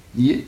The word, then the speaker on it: nier: Un ancien français, nier. Mais je vous dis, alors, dans le cours du XVIe, XVIIe siècle, alors, prononciation actuelle qui s'installe, nier une seule syllabe.